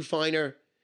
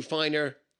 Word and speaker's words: Feiner: Obviously, spread the word to watch me on all my social media, which is Facebook, Stu Finer, Instagram, Stu Finer, and my Twitter feed, which is Stu Source. My last name is spelled F-E-I-N-E-R.